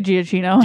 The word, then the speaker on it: Giacchino: Just say Giacchino.